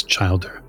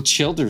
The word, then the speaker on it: childer: So Casper is my childer and it's childer. – Oh, is that how you pronounce it? – Yes, childer. – Oh shit. Okay. Well, childer is kind of cooler because he's, you know, he like chill.